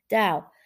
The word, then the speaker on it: Tao: That's hard for me to say because it's a T, but you pronounce it like a D. Tao.